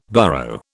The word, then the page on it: burrow: British English